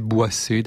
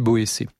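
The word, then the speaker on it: Boësset: Je pense qu'on devait prononcer Boissé d'ailleurs, même si ça s'écrit Boécé.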